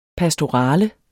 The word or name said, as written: pastorale